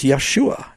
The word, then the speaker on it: Yeshua: In Hebrew, it's Yeshua. And I agree with that. That is the right pronunciation in Hebrew, or close as we know.